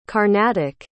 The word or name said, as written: carnatic